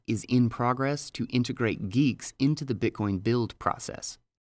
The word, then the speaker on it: GUIX: One such effort is GUIX, pronounced Geeks, which uses functionally declared package management, leading to bit-for-bit reproducible builds by design.